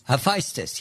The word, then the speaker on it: Hephaestus: Hephaestus, is that how you say it? – Hephaestus? – Hephaestus, yes. – Hephaestus, yes.